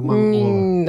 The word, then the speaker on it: монгол: По-моему, из Монголии он был, да. – Ну да, я знаю, что он был из Монголии. Но... Правильно говорить «монголец»? – За монгола. – Наверное. – Монгол. Монгол, точно.